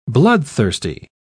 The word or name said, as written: bloodthirsty